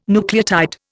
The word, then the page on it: nucleotide: Indian